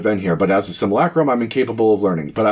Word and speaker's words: simulacrum: You are very astute, but it's pronounced simulacrum. Say it with me. Simulacrum.